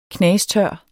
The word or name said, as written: knastør